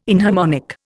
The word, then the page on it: inharmonic: Indian